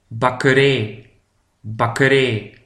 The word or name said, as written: de bakkerij